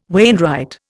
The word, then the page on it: wainwright: Indian